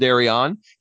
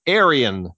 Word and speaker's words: Arion: Aaron Bias said, I had a coworker who loved Arion. He pronounced it Arian. It always caused trouble when he'd ask for them at flea markets, comic shops, etc. No, we don't carry Arian comics.